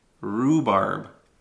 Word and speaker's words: rhubarb: It's got an H, like a rhubarb, but the H is silent. It's just rhubarb, okay? So you don't need to say the H.